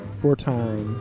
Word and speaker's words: Fortine: 14, like, phonetically pronounced? – Fortine.